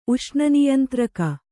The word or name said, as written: ಉಷ್ಣನಿಯಂತ್ರಕ